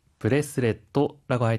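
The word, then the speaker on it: ブレスレット: 하이, 넥크레스, 브레스레트라고 할 때는 중간에 초금이 들어가는데요. 한 박자 쉬었다가 발음하는 점 주의해 주세요.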